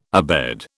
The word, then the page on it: abed: British English